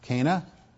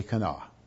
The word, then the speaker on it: Cana: Now, I know you've always heard it pronounced Cana. It's actually Cana. So don't be disturbed if I call it by its actual name today.